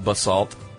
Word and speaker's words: basalt: You said basalt, and she says it's actually pronounced basalt. – Basalt. – With the influence on, or the emphasis on salt.